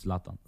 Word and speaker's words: Zlatan: It's Zlatan.